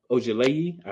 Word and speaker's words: Ojeleye: Some good things that Milwaukee did was they signed Semi Ojeleye. I believe that's how you say it. – Yes. – It's close, if not it.